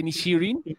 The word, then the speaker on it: Inisherin: La que llega, mira, la de Banshees of Inisherin, así se pronuncia, Inisherin, se estrena en febrero.